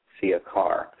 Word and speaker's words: Ciakar: It's spelled C-I-A-K-A-R, Siakar.